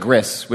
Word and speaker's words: Gris: That's G-R-I-S? The French. Like the French, the gris. – Gris. – You know. – Sure. That sounds. Yeah. – So what is it? – That sounds better than gris, which sounds like something I would eat.